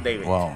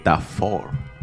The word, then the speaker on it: d4vd: Y gente, nuestros queridos oyentes, David se escribe con la D, el número 4, la V y la D. Pero ah, se dice David. David. – Yo voy a decir Da Four.